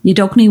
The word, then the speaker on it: Yeldulknie: Mark was also at Yeldulknie Weir Trail for a freedom run.